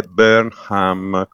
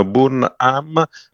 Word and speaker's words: Burnham: e poi è Burnham con l'H aspirata. – Che si deve, quindi la U che quasi una O è la H aspirata, sì, Burnham. – Sì, sì. Sì, basta non pronunciarlo all'italiano Burnham, perché sennò gli inglesi si rivoltano.